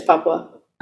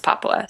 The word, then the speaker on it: Papua: Papua? – It's Papua. – Okay. – Tapir. – Tapir. Okay, I'm looking up tapir, the animal. It's Papua. So I said it right the first time, and then I said it super fucking wrong.